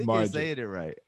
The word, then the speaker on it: Margiela: Mason Margiela. I think you're saying it right.